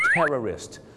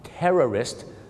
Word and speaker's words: terrorist: terrorist 2 คำนี้มีเส้นบางๆอยู่นะครับในการออกเสียงคือพยางค์ 2 พยางค์ tourist นักท่องเที่ยวกับ terrorist 3 พยางค์ครับผมน่ะกลัวนะครับว่าคุณจะเป็นเหมือนลูกศิษย์ผมคนนึงครับฝรั่งถามว่า Why did you come to America?